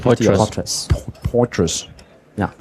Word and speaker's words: Poitras: Poitras. Ja.